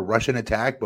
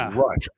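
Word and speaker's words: Rush'n Attack: You guys remember Russian Attack? But we always would pronounce it incorrect. It was Rush. RSH-N-Attack. – Right. – Yeah. – And we would say like Russian Attack, like the Russians are attacking.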